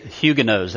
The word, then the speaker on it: Huguenots: The Huguenots was what we usually say, but apparently it should be pronounced, or it is pronounced, Huguenots. Huguenots. That's why I put that there for you. Yeah, Huguenot, I guess, would be the singular. Huguenots would be the plural, the way you would pronounce it.